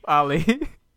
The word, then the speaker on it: Ali: Did I say that right? Ali. – Ali.